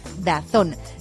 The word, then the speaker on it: DAZN: Pero a estas alturas ya sabes que se pronuncia pronuncia DAZN.